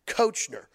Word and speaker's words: Koechner: Nowhere in the English language does the O-E make the short E sound. Nowhere. It's wrong. Kochner. Nowhere is it ka. It's ko.